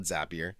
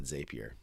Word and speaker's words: Zapier: I call it Zapier. Okay. Because at one point they had a motto that said Zapier makes you happier, but most people actually pronounce it Zapier.